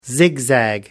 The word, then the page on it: zigzag: American English